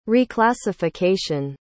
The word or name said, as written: reclassification